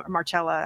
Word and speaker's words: Marcella: But yes, Marcella of Rome, Marcella actually is how you pronounce it.